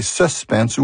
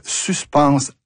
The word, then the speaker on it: suspense: Suspense est de genre masculin et peut se prononcer « suspense » ou suspense. Il existe un mot identique, mais de genre féminin, qui se prononce suspense à la française.